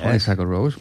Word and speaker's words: Honeysuckle Rose: Honeysuckle Rose, pues lo has dicho muy bien.